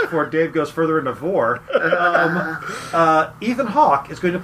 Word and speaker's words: Vore: I mean, he's probably a Vore guy. If you haven't looked up Vore, don't do that. – But it's spelled V-O-R-E, and it's like carnivore.